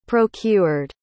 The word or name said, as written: procured